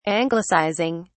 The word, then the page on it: anglicizing: British English